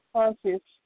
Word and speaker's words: Tarshish: Tarshish? Is that what it is? – Tarshish. Oh, my God. – Tarshish. Okay.